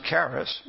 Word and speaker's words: charis: It's spelled C-H-A-R-I-S. We pronounce it charis, but it's pronounced in Greek charis.